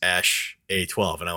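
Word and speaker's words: Æ: And then the symbol is pronounced Ash.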